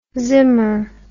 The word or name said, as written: zimmer